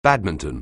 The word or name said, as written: badminton